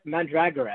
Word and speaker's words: mandragora: They're mandragora, actually. Evidently, how Davidson says to pronounce it.